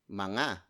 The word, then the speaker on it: mga: Mga, spelled as M-G-A, it's a contraction of the word mga, which is spelled as M-A-N-G-A, and then you add it at the beginning of the word that you want to pluralize.